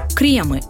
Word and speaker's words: кремы: Во множественном числе в любом падеже это слово стоит произносить с ударением на первый слог. Кремы. Кремом. И обязательно с мягкой «р».